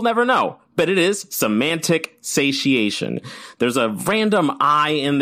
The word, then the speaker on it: semantic satiation: The correct pronunciation of this word is semantic satiation.